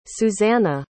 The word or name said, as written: Susana